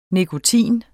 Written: nikotin

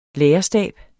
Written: lærerstab

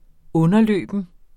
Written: underløben

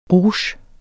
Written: rouge

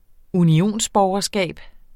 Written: unionsborgerskab

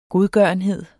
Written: godgørenhed

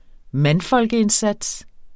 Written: mandfolkeindsats

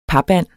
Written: papand

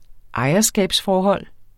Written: ejerskabsforhold

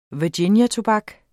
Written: virginiatobak

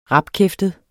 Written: RAPPERE

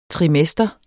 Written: trimester

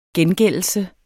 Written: gengældelse

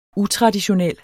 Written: utraditionel

